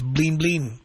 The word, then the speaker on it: blin blin: And even the word bling was used in Spanish, but it was translated into, it's pronounced blin blin, B-L-I-N-B-L-I-N.